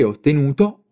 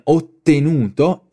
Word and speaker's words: ottenuto: Ora Anthony spiega cosa si può vedere dalla sua esperienza, affermando «Ho sempre ottenuto ottimi risultati». Bravo Anthony! Ricordiamoci del consiglio di prima sulla pronuncia delle doppie. In questo caso una doppia T in ottenuto e ottimi.